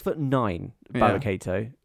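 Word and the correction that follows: Dabba-Kato: Or is it Gabba, what was it? – Dabba Kato. – Dabba Kato.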